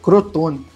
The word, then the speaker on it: Crotone: ficar de olho para trabalhar pronto é o Cotrone, né? Aí eu falei errado. Crotone.